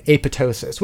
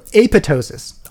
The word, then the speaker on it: apoptosis: So apoptosis, oftentimes pronounced apoptosis, more often than not, in fact, quite often, often enough that the people who pronounce it as it was initially described come across as either snooty or completely ignorant. – Spell it for me. – Okay, so A-P-O-P-T-O-S-I-S. – Okay, I get it. There's clearly an extra P in there.